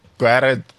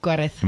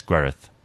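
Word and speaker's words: Gwaredd: Gwared. – I'm probably pronouncing that wrong. Because the double D is actually th in Gwareth. – In Welsh, so it's Gwareth.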